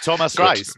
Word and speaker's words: Thomas Reis: Since then, their manager, Thomas Reis, is my apologies if that's the wrong pronunciation. – No, perfect. Thomas Reis. Perfect.